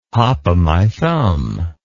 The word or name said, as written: hopomythumb